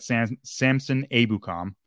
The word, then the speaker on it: Samson Ebukam: But then on the other side, you got Samson Ebukam. Is how you say it.